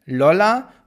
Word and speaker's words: Lolla: Lolla, also so wie äh, Lolli, nur mit A hinten, Lolla und dann Palooza wird so geschrieben wie äh, Palo, P-A-L-O und dann O, also O-O-Z-A. Lollapalooza sozusagen. O-O-Z-A. Lolla Lollapalooza.